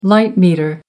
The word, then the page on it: light meter: American English